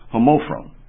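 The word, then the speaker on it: homophron (ὁμόφρονες): And it is spelled H-O-M-O-P-H-R-O-N-T-H-E. Again, that's H-O-M-O-P-H-R-O-N-T-H-E. And it's pronounced homophron. Homophron.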